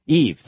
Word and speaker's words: Yves: But my point is that you have, and this is what, how do you say Yves? Eve, thank you, Yves.